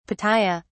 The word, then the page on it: Pitaya: British English